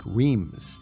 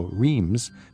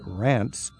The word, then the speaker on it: Reims: R-E-I-M-S. This is one of those towns that are hard to pronounce. It sort of rhymes rhymes with France. – Yes. – But it's spelled like Reims. The average traveler would look at that and go, Reims, but it's Reims. You've got a wonderful ability to say it. How would you say it in French? – Reims. – Reims. – And then you have to pronounce the S at the end, which usually we don't.